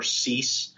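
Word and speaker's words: Siess: You can also find me just with my full name, which would be Christopher Cease. My last name is spelled S as in Sam, I-E-S-S. So I never cease to amaze.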